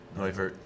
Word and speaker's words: Neuvirth: Neuvirth. – That guy. Neuvirth.